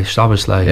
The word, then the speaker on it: Szoboszlai: I mean, it was an awful mistake by Szoboszlai.